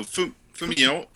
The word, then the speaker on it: Famuyiwa: This is written and directed by Rick Fumagara. How's that sound? Close? – No, Famuyiwa.